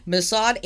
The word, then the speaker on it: Mossad: Mossad. – Mossad agency killed a Palestinian wanted for airplane hijacking by feeding him poisoned Belgium chocolates for over 6 months.